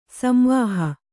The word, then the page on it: ಸಂವಾಹ: samvāha